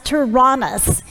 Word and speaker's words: Tyrannus: We're going to see how Paul teaches in the school, and it looks like Tyrannosaurus Rex, but it is actually pronounced Tyrannus.